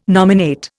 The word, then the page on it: nominate: Indian